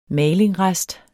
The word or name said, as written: malingrest